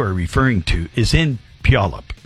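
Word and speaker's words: Puyallup: You know you're pronouncing that name incorrectly, right? – I knew you were going to put me on the air to pronounce that incorrectly. – I'm terrible. – I did my best. – I'm terrible. – I did my absolute best. – It's Pjolup.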